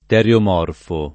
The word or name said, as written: teriomorfo